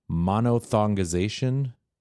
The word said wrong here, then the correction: monophthongization: Yeah, well, there's a PH in there, so I'm not sure I'm pronouncing it right, but it's like diphthong, so I guess it's monophthongization, probably.